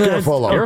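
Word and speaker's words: Garafolo: Well, I am, but it doesn't rhyme with Garoppolo. It's Garofolo.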